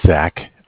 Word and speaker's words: Zach: Well, my name is Zach, spelled Z-A-C-H. – And your last name, please? – Miller, M-I-L-L-E-R.